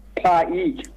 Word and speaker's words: PAEEK: Prosek is a free agent, having left Cypriot side AEL Limassol last year, where he had a loan spell with second tier side Paik, which is spelled P-A-E-E-K.